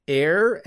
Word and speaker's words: heir: So air and air are pronounced the same way.